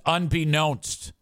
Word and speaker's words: unbeknownst: Idiot says unbeknownst. No, I'm sorry. I fucked it up. It's unbeknownst to me. That's because that's how you say known. KNOWN. The root word is known.